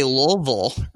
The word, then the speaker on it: Louisville: Lu- Did I say it right? I know I'm supposed to like Lou. – Depends on where you're from. I think the people who are from there, I think they say Louisville or something like that.